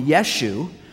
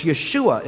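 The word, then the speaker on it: Yeshua (ישוע): They call him Yeshu, which is not his name. Of course, Yeshua is his name in Hebrew.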